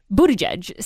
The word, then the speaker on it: Buttigieg: You got to say it like Howie. Buttigieg